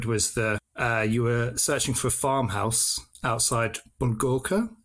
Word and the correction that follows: Bornova: Is that how you pronounce it? Bulgorka? – Bornova, yes. Outside of Bornova, yes. – Ah, okay. Completely wrong.